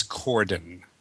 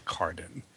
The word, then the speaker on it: Corden: It's James Corden, not Carden.